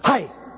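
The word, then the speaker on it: hai (αἱ): That's P-A-S. And then we have a definite article, which is pronounced hai. It's almost like a Japanese word, hai. Only you have to have a sword in your hand. You have to jump and say, hai, hai. That's the word. It's the generic use of the definite article, H-A-I.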